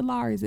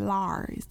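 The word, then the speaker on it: Lawry's: Now I know y'all know what the Laurie's is, and you got to say it like that, Laurie's, the Laurie's, and it's red.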